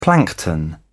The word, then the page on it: plankton: British English